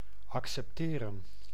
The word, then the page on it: adopter: France; [a.dɔp.te]